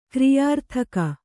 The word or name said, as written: ಕ್ರಿಯಾರ್ಥಕ